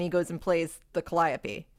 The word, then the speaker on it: calliope: I think it's a calliope. How do you say that? – I don't know. – Because it says calliope. – A calliope? – Calliope. Thank you.